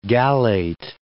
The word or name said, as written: gallate